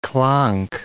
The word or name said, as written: clonk